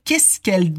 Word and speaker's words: qu'est-ce qu'elle: Mais qu'est-ce qu'elle doit faire là? Mais qu'est-ce qu'elle doit faire là? Attention à la prononciation, on dit qu'est-ce qu'elle? Qu'est-ce qu'elle? Comme si c'était un seul mot.